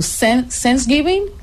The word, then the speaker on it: Thanksgiving: Thanksgiving, eh, está bien pronunciado. Thanksgiving, Thanksgiving.